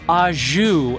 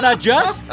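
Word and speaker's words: au jus: The word, is ajou. And you're like, oh, it's not ajus? It's not ajus? Nope, it's ajou.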